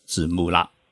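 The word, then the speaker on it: ツムラ: 金春公司在日文当中啊，念作是紫木拉。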